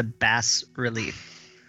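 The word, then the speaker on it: bas-relief: I believe it's a bas relief. Like the fish.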